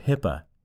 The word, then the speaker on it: HIPAA: The 2009 high-tech legislation expands the security and privacy requirements of Health Insurance Portability Accountability Act, HIPAA, pronounced HIPAA, forcing healthcare organizations to address many facets of electronic health record security.